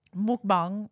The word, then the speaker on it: mukbang: Anyway, while he's doing that, mukbang, which is how it's pronounced, it's a Korean thing.